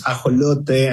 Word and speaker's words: ajolote: In Spanish, it's, I think it sounds nice, right? It's ajolote, and it's an endemic amphibious animal from Mexico.